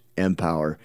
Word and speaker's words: M-Power: So if you want to find out more, it's M-Power, pronounced Empower.